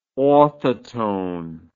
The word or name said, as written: orthotone